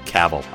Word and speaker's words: Cavill: Henry Cavill had actually... Or Cavill, sorry. Or whatever it is. – When I looked up the phonetic pronunciation, it said, like, travel. So it's Cavill, I guess.